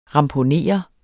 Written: ramponere

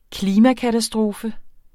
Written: klimakatastrofe

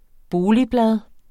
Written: boligblad

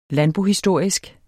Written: landbohistorisk

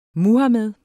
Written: Muhamed

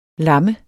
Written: LAMMENDE

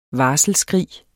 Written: varselsskrig

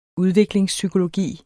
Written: udviklingspsykologi

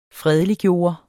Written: fredeliggøre

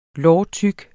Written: lårtyk

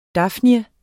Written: dafnie

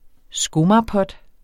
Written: skomagerpot

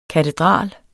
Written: katedral

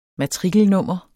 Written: matrikelnummer

